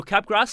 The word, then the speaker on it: Capgras: C-A-P-G-R-A-S, pronounced Capgras, named after a French scientist? – French doctor, yep.